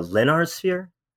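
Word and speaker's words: Lénárt sphere: And one of the things that fascinated me looking through the book was the Lennard sphere. Am I pronouncing that correctly? – Yes.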